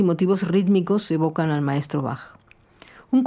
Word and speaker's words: Bach: Esta Bachiana, porque la che de Bach se pronuncia con sonido K, tiene la estructura de un aria da capo, ABA.